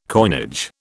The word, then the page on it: coinage: British English